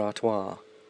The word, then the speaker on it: Artois: Oh, yeah, like the drink Stella Artois, but she's put Artois. That's how Americans say twat, twat. It's actually twat. I'm sure you can pronounce at, you crazy Americans, but you'd rather say twat.